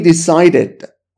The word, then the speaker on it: decided: Decide وقتی میره به گذشته و ایدی میگیره، ایدی رو به این صورت تلفظ میکنیم. Id. We decided.